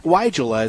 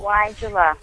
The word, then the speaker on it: Weigela: Another question I have is my wine and roses, Regalia, how do you pronounce that? – Weigela. It's named after a German botanist named Weigel. – Weigela.